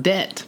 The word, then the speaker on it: debt: We are talking about debt. – what a fun word. – Mm-hmm. That is a fun word. Debit. – Debit. nice. – There's a silent B in case you didn't know.